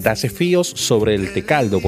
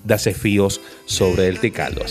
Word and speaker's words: Dasefíos sobre el Tecaldo: Y vaya que no es fácil decirlo, amigos oyentes, Dasefíos sobre el Tecaldo, porque la memoria lo lleva a uno a pronunciarlo como es, pero no, ya usted sabe por qué es Dasefíos sobre el Tecaldo.